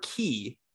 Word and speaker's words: Quay: Minnie Quay, Q U A Y, or Key, because that's also how you pronounce the word. Q U A Y is key, but mm-hmm.